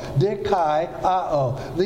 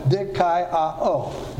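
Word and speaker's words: dikaioō: D-I-K-A-I-O-O. Dikaiō. These are a diphthong, A-I pronounced I, and then short O, A, and then long O, O. Dikaiō. Dikaiō. And so that's how that would be pronounced.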